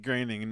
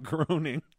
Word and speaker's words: Groening: It's pronounced Groening, not Groening.